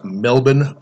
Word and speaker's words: Melbourne: Melbourne home.